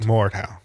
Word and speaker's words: Mordhau: Is Mordhau. – Is that how it's pronounced? – Mordhau.